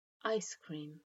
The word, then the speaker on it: ice cream: When Brits ask for ice cream, they stress the second word. Americans stress the first, ice cream.